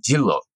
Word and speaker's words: Jinro: It's the largest soju maker called, it's spelled J-I-N-R-O, and Westerners will pronounce that Jinro, which is actually incorrect. Even though you're saying it exactly like you should by reading that word, in Korea, they will pronounce it Jillo, almost like it's J-I-L-L-O, basically.